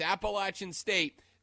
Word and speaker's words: Appalachian State: Like, and it's dumb because, like, jokes aside, Diesel was so adamant in making sure that I understood that it's Appalachian State that, A, I have not mispronounced it since that day, and B, he was so genuine about making damn sure that I understood how to correctly pronounce Appalachian State that I walked out of there,